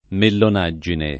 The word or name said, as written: mellonaggine